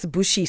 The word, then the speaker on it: Bouche: And joining me online, I've got our guest, Alex BOUCHE. He's the director of Marketing Kingsmead Bookfair. That's Alex BOUCHE.